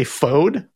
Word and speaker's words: Fode: I'm going to say Fode. Does that sound right? – Yep.